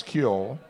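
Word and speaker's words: ceol: But it's C-E-O-L-L, C-E-O-L, that's Kjol, ampersand. Looks like the thing on the Cheerios box. Yeah. And crack, C-R-A-I-C, right? – What's a crack?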